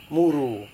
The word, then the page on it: muru: Serewen